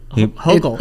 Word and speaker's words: Hochul: Did he get that right? He got it right? Okay. – Hochul. It's Kathy Hochul. – We mispronounce it a lot.